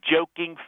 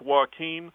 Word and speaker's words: Joaquin: And who better to portray the Joker than Joking Phoenix? I mean, the title's in his name, for God's sakes. – Sir, I believe it's pronounced Joaquin.